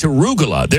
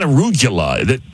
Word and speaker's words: arugula: But man, it's arugula. It's not arugula. He's adding syllables.